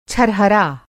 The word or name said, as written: छरहरा